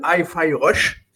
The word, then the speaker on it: Hi-Fi Rush: Nous avons aussi du Hi-Fi Rush. C'est bon, je le prononce bien Oui, oui, ça va très bien.